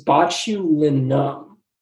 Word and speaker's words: botulinum: It's botulinum.